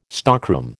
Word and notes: stockroom: American English

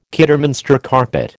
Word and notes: Kidderminster carpet: American English

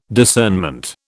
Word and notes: discernment: British English

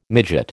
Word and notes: midgut: American English

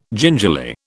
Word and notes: gingerly: British English

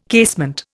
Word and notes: casement: Indian

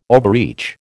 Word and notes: overreach: American English

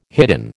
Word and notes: hidden: American English

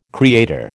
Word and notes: creator: American English

